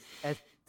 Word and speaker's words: SZA: Oh yeah, SZA, GZA, RZA. – Yeah, I remember when she first started popping. Mm-hmm. People were just like, "How do you pronounce this? SZA, SZA."